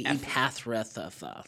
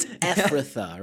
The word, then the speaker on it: Ephrathah: Because I always want to say Epathrathah. – Yeah, because of the way it's spelled. When you read it, it's like, the first time I read it, I said Epathrathah. – Yeah. But it's Ephrathah, right? – Yeah.